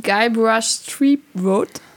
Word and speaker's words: Guybrush Threepwood: Ähm, Guybrush Threepwood.